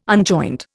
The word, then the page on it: unjoint: Indian